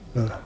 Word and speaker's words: Norah: ¿No cambia la pronunciación o qué? – No cambia. – Norah, ¿no? – Norah. La de Ibsen suena igual, ¿no? – Como la madre de Borges, ¿no?